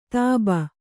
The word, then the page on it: ಧೋಬಿ: kannada